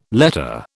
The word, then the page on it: letter: British English